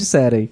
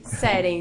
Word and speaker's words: Seren: Conversamos então com os representantes da startup Seren, como eu pronuncio corretamente? – Da forma como você quiser, eu chamo de Seren. – Seren, então. Startup Seren, que vão implementar, e já estão implementando aqui, o projeto piloto de educação vocacional para o município de Marau.